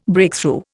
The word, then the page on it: Breakthrough: Indian